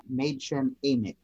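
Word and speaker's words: Mädchen Amick: and I had to look up several times how to pronounce her name. Madgen Amick, apparently. – Okay, I've never heard it pronounced. I've only seen it written. So. – Yeah, both of the A's are long A's apparently, and certainly, you know, all three were launched to fame.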